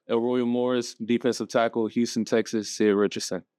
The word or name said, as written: Elroyal Morris III